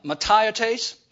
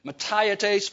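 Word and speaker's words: mataiotes (ματαιότης): The word futility in the original language is mataiotes. Mataiotes, phonetically spelled M-A-T-A-I-O-T-E-S.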